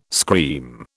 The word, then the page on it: scream: British English